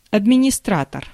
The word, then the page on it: directeur: France; /di.ʁɛk.tœʁ/